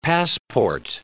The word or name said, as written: passport